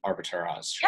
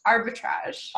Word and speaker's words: arbitrage: What, like, explain the difference between a regular Airbnb and then an Airbnb arbitrage. – Yeah. Arbitrage. – Arbitrage, yes. Yeah. I butchered that.